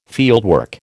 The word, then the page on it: fieldwork: American English